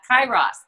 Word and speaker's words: Kairos: Okay, so you talk about something called Keros time. Am I saying that right? – I had to look up the pronunciation for that. Kairos time.